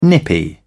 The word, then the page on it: nippy: British English